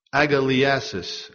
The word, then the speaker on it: agalliasis: This word for gladness is taken from the Greek word agaliasis. Agaliasis, I'll spell it. A-G-A, L-L-I-A-S-I-S, agaliasis. It literally means exaltation, extreme joy.